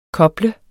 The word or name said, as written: koble